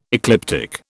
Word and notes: ecliptic: British English

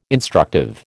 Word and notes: instructive: American English

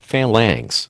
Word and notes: phalanx: American English

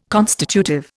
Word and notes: constitutive: Indian

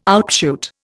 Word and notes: outshoot: Indian

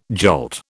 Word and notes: jolt: British English

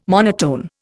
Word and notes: monotone: Indian

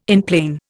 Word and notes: enplane: Indian

